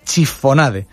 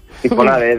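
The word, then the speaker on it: chiffonade: Que no sé si lo he dicho bien, y ahí puede corregirme, pues su autor. ¿Qué tal? Buenos días. – Buenos días. Sí, lo ha dicho bien. Chifonade.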